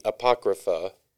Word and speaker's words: Apocrypha: So you have like, you know, the book of Maccabees, which is in the Apocrypha. I'm doing that right, right? – Yeah, you're still saying it right.